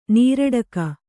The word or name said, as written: ನೀರಡಕೆ